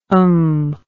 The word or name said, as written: umph